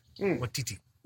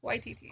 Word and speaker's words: Waititi: Waititi. – Waititi.